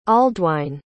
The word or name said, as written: aldwine